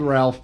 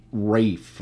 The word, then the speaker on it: Ralph: It's spelled Ralph, but it's Rafe.